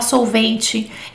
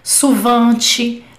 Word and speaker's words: souvent: Não pronuncio o T, é suvan, suvan. Tem gente que, já, que eu já ouvi pronunciando suivan, ui com ui, que é, é outra palavra, é outra coisa, não é, não é isso aqui, tá?